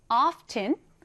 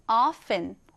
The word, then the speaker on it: often: 자, 발음할 때는요, 두 가지 방법이 있습니다. 첫 번째는 이 t를 살려서 often. 두 번째는 t 발음을 생략한 채로 often이라고 하는데요. 여러분은 often, often 어떤 것이 더 마음에 드시나요? 사실은 두 번째, T를 생략한 채로 발음하는 것이 훨씬 더 흔히 사용되긴 합니다.